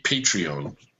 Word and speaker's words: Patreon: Joe McDaldno brought to my attention the Patreon website. And I, oh I my god, think I'm pronouncing that right. It's like yeah. patron with an E randomly yes, thrown in there.